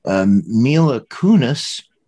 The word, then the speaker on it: Mila Kunis: Mila Kunis. Did I pronounce that right? – Yes.